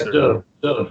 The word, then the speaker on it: Derleth: So you worked with August Derleth, and I hope I pronounce his last name right. – Yeah, Derleth, I believe so.